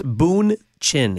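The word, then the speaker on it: Bündchen: Fer, tú nos estabas diciendo que Giselle Blanchett gana más dinero que Tom Brady. – Boonchin, Enrique. Let's say this right. It's Boonchin. – ¿Y qué dije yo? – Blanchett, you've said like 10 different last names.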